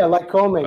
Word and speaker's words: Lycoming: Lycoming? – Yeah, Lycoming.